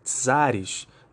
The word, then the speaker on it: czar: que está sob a liderança de vários czares, né, se pronuncia como se fosse T, se escreve com C. Então, escreva aí C-Z-A-R, o czar, né, que é um título monárquico utilizado por alguns países do leste europeu, é, principalmente a Bulgária e os russos.